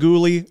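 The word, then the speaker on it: Guhle: Caden Gooley, Joel Edmondson, and then Jordan Harris and Jonathan Kovacevic. – I believe you pronounce it. It's Caden Gooley.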